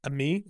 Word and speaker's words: Amie: But there's this new app called Ami. It's like, it's spelled like Amy, like amiable, like the friend in French, A-M-I-E.